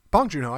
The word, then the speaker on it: Bong Joon Ho: Uh, our next story from Cannes Film Festival: uh, Bong Joon Ho's um, Bong Joon Ho, I think I said that right.